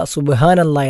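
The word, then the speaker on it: സുബ്ഹാനല്ലാ (سبحان الله): സുബ്ഹാനല്ലാ, അൽഹംദുലില്ലാ, ഇവിടെ രണ്ടും ഹാ എന്ന അക്ഷരമാണ് ഇവിടെ ഉച്ചരിക്കേണ്ടത്. ഹാ എന്ന അക്ഷരം തൊണ്ടയുടെ മധ്യഭാഗത്തു നിന്നും പുറപ്പെടേണ്ട അക്ഷരമാണ്. സുബ്ഹാനല്ലാ, സാധാരണ ആളുകൾ സുബ്ഹാനല്ലാ എന്ന് കെട്ടുള്ള ഹാ തൊണ്ടയുടെ ഏറ്റവും താഴ്ഭാഗത്തു നിന്നും പുറപ്പെടുന്ന അക്ഷരമായി ഉച്ചരിച്ചു വരാറുണ്ട്.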